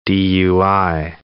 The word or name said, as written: DUI